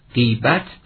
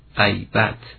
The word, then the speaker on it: غیبت: اما غیبت کردن یعنی بدگویی کردن رو باید غیبت تلفظ کنی مثل قیمت، اما واژه‌ای که در طباق با حضور و حاضر است غیبت به فتح نخست.